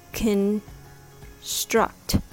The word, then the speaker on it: construct: Construct is pronounced construct. The word has the stress on the second syllable. The first syllable has the schwa sound and are short and weak.